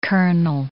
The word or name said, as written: kernel